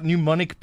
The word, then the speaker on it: pneumonic: While we're talking about something that's worrying, should we be worried about pneumonic plague? There's a new word, and it's got a P and an N. So the P is silent.